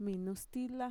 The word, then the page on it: minustila: Mixtec